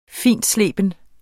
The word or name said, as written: fintsleben